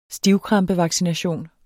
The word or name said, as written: stivkrampevaccination